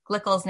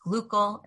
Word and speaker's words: Glickel: Glickel's name is sometimes pronounced Glückel in the more Germanized pronunciation, but I'm going to be referring to her as Glickel.